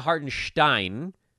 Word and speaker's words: Hartenstein: I always love when players are in the league for 5 years and then they tell everybody that the media guide has their name wrong. So it's Isaiah Hartenstein.